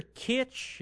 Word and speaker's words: Kitsch: Taylor Kitsch, is that right, from Friday Night Lights? – I think that's his name, yeah. I think that's how you say it.